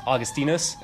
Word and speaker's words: Augustinus: I agree that in English we pronounce Augustine, but in Latin his name is Augustinus, and so it's actually acceptable to say, if you want to be Latin, to say Augustine.